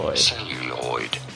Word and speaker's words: celluloid: How do you say it? Celluloid. – Celluloid. – You do want to stress on the first.